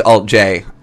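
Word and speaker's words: alt-J: And that was the track Fitz Pleasure by Alt J, which is spelt, actually is the, it's just a little triangle, but it's pronounced Alt J.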